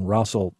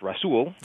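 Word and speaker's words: Rasul: Russell v. Bush. – Sure. – Essentially there, what did the court say? – Well, it actually pronounced Russell.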